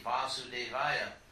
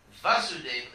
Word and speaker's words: Vasudeva: And actually, it's like, we chant that Om Namo Bhagavate Vasudevaya. Vasudeva, the short A, Vasudeva is Krishna's father. With a long A, Vasudeva, that's Krishna.